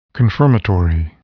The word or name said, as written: confirmatory